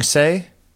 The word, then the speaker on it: Hsieh: Teshing Heshe? – Mm-hmm. – Maybe? Se? Maybe. Teshing Se? – Se, yeah, see? – I'm terrible with pronouncing. – H-S-I-E-H.